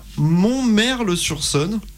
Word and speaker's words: Montmerle-sur-Saône: Et vous serez aussi le 30 août à Montmerle-sur-Saône.